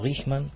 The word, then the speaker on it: Riechmann: Si lo quisiéramos decir en alemán, sería algo así como Riechmann, que quiere decir aproximadamente olfateador o hombre que husmea. Pero, bueno, mucha gente lo pronuncia en español de esa manera.